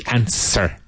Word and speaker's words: answer: Why doesn't he know the answer? Remember, the W is silent in answer.